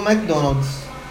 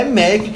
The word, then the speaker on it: McDonald's: Devido a tanta gente chamar de MC Donald e não saber como é que fala Mac, Mac, como é que chama. Vamos simplificar? E eles criaram essa nova campanha escrevendo M-E-Q-U-I, Mac. É Mac que se fala, né?